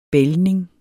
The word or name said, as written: bælgning